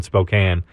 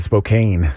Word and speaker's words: Spokane: Especially the way that Spokane. – Spokane. – Spokane was the typical pronunciation there. – You'd be surprised how many people actually pronounce it like that.